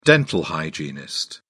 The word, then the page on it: dental hygienist: British English